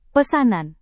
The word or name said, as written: pesanan